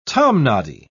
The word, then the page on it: tomnoddy: British English